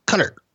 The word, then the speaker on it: Qatar: Every time I pronounce it Qatar, people would say it's not like guitar, it's Qatar. And I thought, wow, I feel pretty stupid. Now, I will tell you that when I went over there, it's pronounced both ways by people that live there.